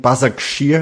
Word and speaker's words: Başakşehir: But that's, I think, Başakşehir.